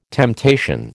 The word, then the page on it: temptation: American English